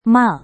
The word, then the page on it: mal: Indonesian